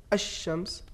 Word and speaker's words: الشمس: تو حروف شمسی میں ادغام ہوگا، مثال کے طور پر الشمس، تو جب ہم ادغام کریں گے، ادغام کرتے وقت پڑھیں گے الشمس، یعنی لام کو تلفظ نہیں کریں گے بلکہ صرف شین کا تلفظ ہوگا تشدید کے ساتھ، تو یہ ادغام متقاربین کہلائے گا.